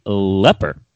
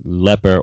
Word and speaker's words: Ypres: but I believe the proper pronunciation is actually leper. But it's spelled Y-P-R-E-S, and you would think it would be pronounced Ypres. – Yes. – Or Ypres. But I believe the actual local pronunciation is leper or yepper.